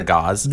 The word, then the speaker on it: Gauss: I've heard it as Gauss. I think Gauss is the actual official way. I hate it so much. I prefer Gauss.